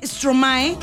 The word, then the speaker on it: Stromae: Esta rola que está a cargo de Stromae, así se pronuncia.